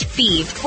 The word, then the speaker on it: Fives: Thieve is spelled F-I-V-E-S, like the number, but pronounced Thieve.